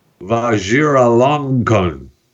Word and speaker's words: Vajiralongkorn: King Maha Vajiralongkorn.